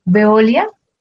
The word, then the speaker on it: Veolia: Veolia, agradezco me confirmen si la pronunciación es correcta.